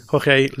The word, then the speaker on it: Jorge: Is it Jorge? – Jorge Luis Borges, yep.